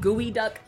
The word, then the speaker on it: geoduck: It's called gooey duck, but it's spelled G-E-O-D-U-C-K.